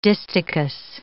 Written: distichous

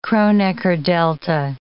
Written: Kronecker delta

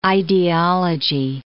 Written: idealogy

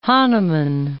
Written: Hahnemann

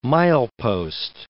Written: milepost

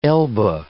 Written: Elba

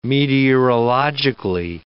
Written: meteorologically

